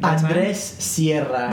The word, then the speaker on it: Andrés Sierra: Andrés Sierra.